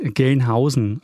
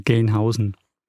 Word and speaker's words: Gelnhausen: Richtig ausgesprochen ist es eigentlich Gelnhausen. Also man schreibt es nur mit einem L, aber man spricht es aus wie beim Geld, also Gelnhausen.